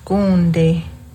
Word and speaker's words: gonde: And going back to eel, gonde.